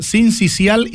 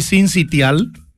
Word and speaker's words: sincicial: ¿Sincicial y sincitial? – Sí, se puede decir de las dos maneras.